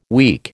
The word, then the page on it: week: American English